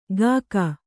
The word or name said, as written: ಗಾಕ